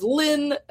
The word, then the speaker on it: Lynn: my middle name is Lynn, L-Y-N-N.